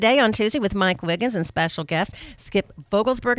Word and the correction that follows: Vogelsang: Am I pronouncing that correctly? – Vogelsang. – Okay, I knew that was close.